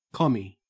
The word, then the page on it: commie: Australian English